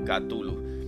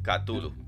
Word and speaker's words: Cthulhu: The Madness of Cthulhu. Pero Cthulhu no se escribe como yo lo estoy mencionando. Se escribe c t -H -U.